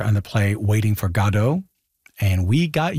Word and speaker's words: Godot: But we did read that Beckett himself said Waiting for Godot.